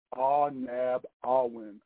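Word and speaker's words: Ah-Nab-Awen: It's called Ah-Nab-Awin. I'll spell it for you. It is Ah, like A-H, Nab, N-A-B, Awin, or Awin, A-W-E-N, Park, which is located at 220 Front Avenue Northwest in Grand Rapids, Michigan.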